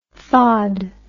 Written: thawed

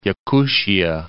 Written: Yakutia

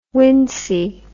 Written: wincey

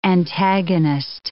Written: antagonist

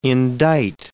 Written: indite

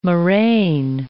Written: moraine

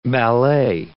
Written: ballet

